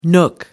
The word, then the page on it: nook: American English